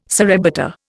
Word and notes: surrebutter: Indian

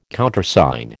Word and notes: Countersign: American English

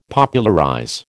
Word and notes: Popularize: American English

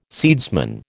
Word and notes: seedsman: American English

